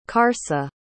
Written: karsa

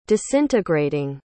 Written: disintegrating

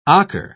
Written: ocker

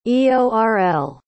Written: eorl

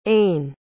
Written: ain

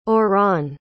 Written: orran

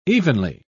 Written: evenly

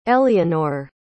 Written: elienor

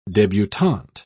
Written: debutante

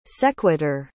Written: sequitur

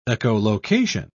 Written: echolocation

